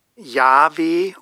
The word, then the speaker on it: Jahwe: Diejenigen, die wie im hebräischen Text den Eigennamen Gottes von den Titeln Gott und Herr unterscheiden, fügen traditionell die Vokale A und E oder auch E, O und A hinzu. Und so kommt man dann zu der Aussprache Jahwe oder Jahwe oder auch Jehova.